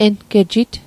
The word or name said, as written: engejit